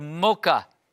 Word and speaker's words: Moca: Live on the island of Puerto Rico in a small town called Moca, where the first Adventist church was built on the island. M-O-C-A. Did I say that right?